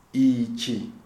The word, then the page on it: Ichi: Shona